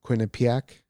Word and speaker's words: Quinnipiac: And I actually, I might have to eat my words because uh, uh, one of those polling places, Quinnipiac, mm-hmm.